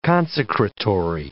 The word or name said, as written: consecratory